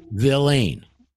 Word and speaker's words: villein: Go to Black's Law Dictionary, and you look it up, and it's going to be spelled V-I-L-L-E-I-N, villain. We say villain. It's spelled villain.